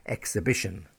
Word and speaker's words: exhibition: E-X-H-A-L-E. And in this sense, the H is stressed. Okay, so but in all others, we have a silent H for exhibition.